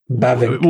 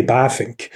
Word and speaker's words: Bavinck: So I think as long as you put emphasis on the first syllable, so it's not Bavinck, it's Bavinck. – Right. Okay. – But then, in Dutch, the letter V is pronounced as an F in English. So it's really Bavinck.